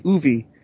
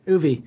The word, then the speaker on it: Oovee: How do you say that? Uvi? – That's exactly how it's spelled. O-O-V-E-E. Uvi. – Uvi.